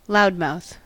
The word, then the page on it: loudmouth: American English